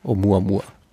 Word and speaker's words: Oumuamua: Der Name ist das ist ein hawaiianischer Name. Oumuamua. – Oumuamua. So? – Oumuamua. – Hast du die richtige Aussprache auch? – Ja, O-U-M-U-A-M-U-A.